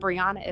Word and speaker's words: Breeanna: Maiden name is Brown, but Brianna is spelled B-R-E-E-A-N-N-A.